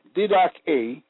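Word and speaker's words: didache (διδαχή): The word doctrine in Greek, pronounced didache, meaning instruction, the act or the matter, or what is thought.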